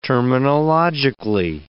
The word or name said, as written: terminologically